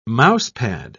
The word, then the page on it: mousepad: British English